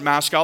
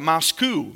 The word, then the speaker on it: Moscow: It's spelled Moscow, but they don't call it Moscow. They call it Moscow. They don't like the Russian sound, so they call it Moscow, Idaho.